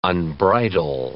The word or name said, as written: unbridle